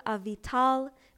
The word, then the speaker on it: Avital: You can find me on Facebook under my full name, Avital, which is spelt like A Vital, last name Miller.